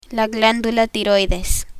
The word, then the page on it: tiroides: spanish